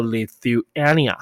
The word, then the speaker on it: Lithuania: the u through okay through th 发 through through okay so Lithuania 哈 Lithuania Lithuania Lithuania so Taiwan okay is going to invest 10 million dollars 那我拿不是 dollars 是美元 okay 10 million euros toward chip production in Lithuania